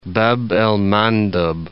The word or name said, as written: Bab el Mandeb